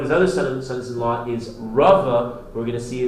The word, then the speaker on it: Rava: The first, Rabba, is with a base. Rabba versus Rava. Rava with a vase,